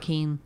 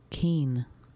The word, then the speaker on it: Kean: They spell it Kane, K-E-A-N. – Keen. – K-E-E-N. – Is that what you think, why it's Kane? Well, it's just a random pronunciation. – How do you spell scene? S-E-E-N. Rhyme scene with Keen.